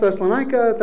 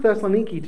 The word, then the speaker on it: Thessaloniki: So their, their short uh, but sweet success in Thessaloniki. Uh, it can be pronounced Thessalonica, Thessaloniki. It's pronounced Thessaloniki today.